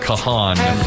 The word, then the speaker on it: Kahan: I said to BB Shay, how do I pronounce his name? Because it's spelled like Kahan. He goes, it's Kahan.